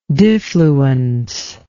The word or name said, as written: diffluence